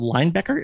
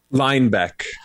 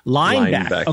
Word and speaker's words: Linebeck: Hey, man, the band's called Linebacker. Am I saying that right? Or is it Linbecker? – Linebeck. – Linebeck. Okay. – It's like a lineback, but with an E.